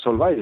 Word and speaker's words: Solvay: Y, has elegido como tema eh, el método Solvay o Solvay. ¿Cómo ¿Cómo lo, cómo lo pronuncias? – Pues Solvay está bien, Solvay.